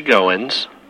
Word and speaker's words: Goins: My name is Larry Goins. – How do you spell that? – G-O-I-N-S.